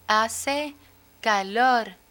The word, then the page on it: hace calor: Spanish